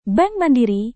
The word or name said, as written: bank mandiri